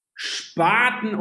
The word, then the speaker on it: Spaten: Spotten, right? My pronunciation. So these are both Munich beers. – Spaten or Hackerbräu? – Yes. – Oh my God. – Sorry. – Really? – Sorry for my American pronunciation.